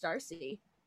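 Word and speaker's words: D'Arcy: So the first picture is of Emma Darcy, is that how we say her name? I think it's Darcy. – Yeah, like, I think it's right.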